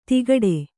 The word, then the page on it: ತಿಗಟೆ: tigaṭe